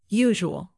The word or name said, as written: usual